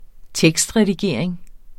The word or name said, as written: tekstredigering